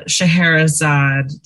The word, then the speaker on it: Scheherazade: We're here with Daphne Suman, author of The Silence of Scheherazade.